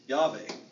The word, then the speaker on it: Yahweh: If I add an A right there, okay, and I add an E right there, Yahweh, they would, Jewish in Hebrew, you'd pronounce the W as a V. Yahweh. Okay? So now we hear the name of God.